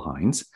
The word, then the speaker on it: Hynes: And my last name is spelled H-Y-N-E-S, so it's Michael Hines.